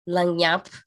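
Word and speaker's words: lagniappe: And we have another one from Ethan, langyap. I've been pronouncing this word wrong for the last decade. It's L-A-G-N-I-A-P-P-E, langyap.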